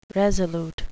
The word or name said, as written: resolute